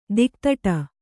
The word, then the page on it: ದಿಕ್ತಟ: Kannada